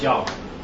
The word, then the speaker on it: Yahweh: We pronounce it as Yahweh.